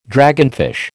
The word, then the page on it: dragonfish: American English